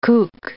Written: kook

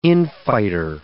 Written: infighter

